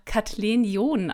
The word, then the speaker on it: Kathleen John: Und ich habe gesagt, ähm, Kathleen John, das hört sich so schön amerikanisch an oder aus dem äh, englischen Adelshaus, aber tatsächlich wird mein Name ganz plump einfach Kathleen John ausgesprochen, so ganz hochdeutsch und total unexklusiv.